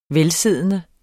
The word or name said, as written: velsiddende